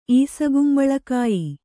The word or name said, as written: ಈಸಗುಂಬಳಕಾಯಿ